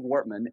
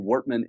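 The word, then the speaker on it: Wortmann: At Sales Engine and at Craig Wortman. And I've got a crazy last name, so I'll spell it. It's just C-R-A-I-G, Craig. And then Wortman is W-O-R-T as in Tom, M-A-N-N, two N's on the end.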